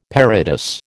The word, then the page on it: parados: American English